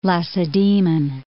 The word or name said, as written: Lacedaemon